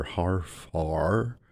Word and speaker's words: Hfuhruhur: Steve Martin plays a title role in the film, Dr. Harfar. It's pronounced just like it's spelled, H-F-U-H-R-U-H-U-R.